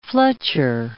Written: fletcher

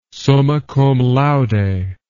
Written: summa cum laude

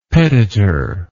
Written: petitor

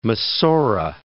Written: Masora

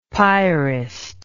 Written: piarist

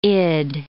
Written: id

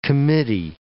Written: committee

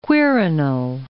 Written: Quirinal

